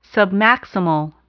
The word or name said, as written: submaximal